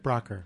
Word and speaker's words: Braucher: Patrick Braucher.